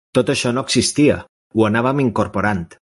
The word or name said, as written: existia